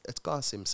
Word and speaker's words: Qasim: Qasim. And it's spelled Qasim. Mm-hmm. So that's the difference. It's the Arabic, the way you say it, and the name is Arabic.